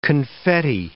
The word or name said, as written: confetti